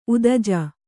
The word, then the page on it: ಉದಜ: udaja